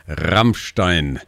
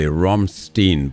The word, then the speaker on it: Rammstein: Yeah, that was the number one hit with the group, by the group Rammstein. You would say Rammstein, but the real pronunciation is Rammstein.